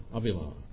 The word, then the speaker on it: Avila: Avila, that's how you say it. You don't say Avila, Avila.